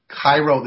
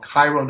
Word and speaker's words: Cairo: Cairo, there's a town called Cairo, New York. They like to pronounce it Cairo. They like to disassociate themselves from the Egyptian connection and all that it represents.